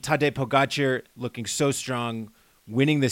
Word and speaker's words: Pogačar: So as we saw at Puy-Marie, this race has really separated into a two-man battle between Tadej Pogačar and Tadej Pogačar. If someone says you've been putting the emphasis on the wrong syllable, so Tadej Pogačar and Primož Roglič.